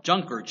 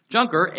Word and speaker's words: Juncker: We read the following, quote, European leaders in Brussels have nominated Juncker, J-U-N-C-K-E-R. I may be pronounced different in French, but J-U-N-C-K-E-R, Juncker.